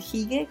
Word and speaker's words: hygge: que es el hige, como se escribe H-Y-G-G-E,